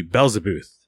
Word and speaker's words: Belzebuth: And speaking of horror films, I did also really love Belzebuth, and that's the way the character pronounces it in the movie, Belzebuth.